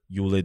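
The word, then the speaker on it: Yul: Is he Yule or Yol? – Yule Doche, the son of an Afrobeat Nollywood legend, Peter Doche.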